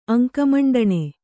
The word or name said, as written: ಅಂಕಮಂಡಣೆ